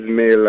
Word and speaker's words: Mail: Yes, it is Mail, like from the... – Yes, Mail, like email.